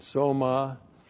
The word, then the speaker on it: soma: And soma, S long O M A, soma is the Greek word body, your physical body.